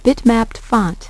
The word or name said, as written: bitmapped font